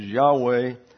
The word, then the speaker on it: Yahweh: We have also the Jewish pronunciation, which is Yahweh.